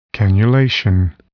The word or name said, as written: cannulation